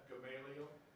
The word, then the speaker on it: Gamaliel: Gamaliel. – Pronounce it. What's that? – Gamaliel.